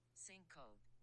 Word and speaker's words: syncope: Syncope.